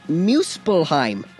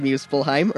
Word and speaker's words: Muspelheim: There is Muspelheim. I think it's pronounced Muspelheim or Muspelheim.